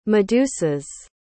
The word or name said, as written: medusas